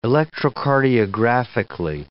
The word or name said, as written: electrocardiographically